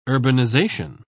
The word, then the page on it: urbanisation: British English